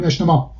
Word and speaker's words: اجتماع: یا کلمه اجتماع، در کلمه اجتماع به جز در آخرش که این انسداد چاکنایی وجود داره که ما تلفظ نمی کنیم، مثلا میگیم اجتماع آخرش آ هست، ولی می نویسیم، وجود داره، می نویسیم ولی تلفظ نمی کنیم. حتی بعد از الف اول اون جیم ش تلفظ میشه، میشه اجتماع، اجتماع، اجتماع.